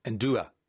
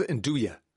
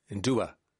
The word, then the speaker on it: nduja: Rory's been on Not Being Funny, but the correct pronunciation of N-D-U-J-A is Ndua. I thought it was Nduya. Ndua.